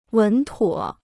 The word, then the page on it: 稳妥: wěn tuǒ